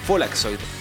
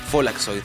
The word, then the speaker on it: Follakzoid: Ellos se llaman Folaxoid, se escribe F-O-L-L-A-K-Z-O-I-D. Folaxoid, que es eh, como decir encendedor en alemán, pero pronunciado pues así, Folaxoid.